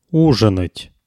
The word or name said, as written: uzhinat